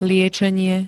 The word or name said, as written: liečenie